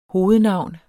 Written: hovednavn

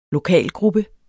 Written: lokalgruppe

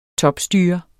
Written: topstyre

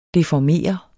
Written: deformere

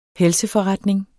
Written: helseforretning

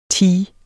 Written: tee

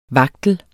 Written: vagtel

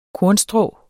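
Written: kornstrå

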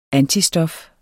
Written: antistof

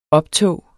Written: optog

